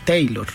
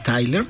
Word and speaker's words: Tyler: Entonces mucha gente le dijo Bonnie Taylor. Ajá. Pero en realidad Bonnie Tyler es una de las voces más representativas.